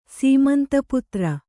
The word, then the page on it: ಸೀಮಂತಪುತ್ರ: Kannada